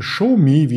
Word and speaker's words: Xiaomi: mit der Alibaba und mit der ShowMe, wie es ausgesprochen wird.